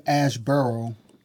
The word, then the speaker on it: Asheboro: So in Asheboro, right? I said it right? – Mm-hmm.